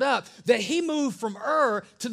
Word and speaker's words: Ur: Everybody say Ur. Don't say it like a lion. Like Ur. No, just Ur.